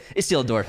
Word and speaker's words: Isildur: And speaking of Gandalf, you're laughing, you're smiling because I said it right. Isildur, I got it.